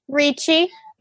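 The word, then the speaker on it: Ricci: Christina Ritchie. How do you say it? – Ritchie.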